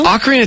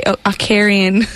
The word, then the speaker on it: ocarina: I want to say Ocarian. – No, it's an ocarina.